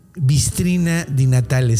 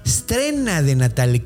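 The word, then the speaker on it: strenna di Natale: No se dice Bistrina di Natale, se dice Estrena de Natale, que está todavía más, más, eh, mm, más cercano.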